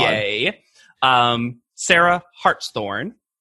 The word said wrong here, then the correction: Hartshorn: Hartsthorn. H-A-R-T-S and then horn with an E on it. – Hartshorn.